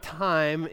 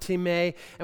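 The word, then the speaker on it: timē (τιμή): The word honor is the word timē, not time. In Greek, it's pronounced timē.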